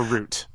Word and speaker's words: route: Do you say route or root? – Oh, you're right. – Root. It's definitely meant to Knuckles is saying root.